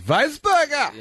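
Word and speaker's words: Wiesberger: You've got to say it like this. Weisberger!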